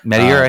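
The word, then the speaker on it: Metayer: I think that's how I said that right. Am I saying that right? – Medier, I think. Yep.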